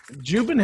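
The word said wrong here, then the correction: Jouban: Am I saying that wrong again? – Joban. – Why can't I say it right?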